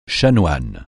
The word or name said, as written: chanoine